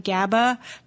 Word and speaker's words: GABA: So it's good to know that that is actually GABA. And Jed said it correctly, that it exerts its primary sedative and hypnotic effects via interaction with the GABA receptor.